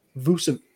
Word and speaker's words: Vucevic: Speaking of another trade, though, the Bulls went out and got Nikola Vucevic.